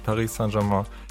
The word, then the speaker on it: Paris Saint-Germain: Ich weiß nur, wie es ausgesprochen wird und das ist Paris Saint-Germain.